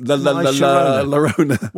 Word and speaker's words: La Llorona: It's unfortunate that the titular antagonist's name, La Llorona, rhymes with the popular Knack single. – Yes, La Llorona.